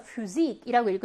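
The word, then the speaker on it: Physik: 폐하는 여러분 F 발음이에요. 그래서 Physik이라고 읽으셔야 하고요.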